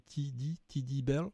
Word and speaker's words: Teddy Bell: Alors Teddy, c'est pas Teddy, hein, c'est T et D. Voilà, on prononce en anglais Teddy Bell.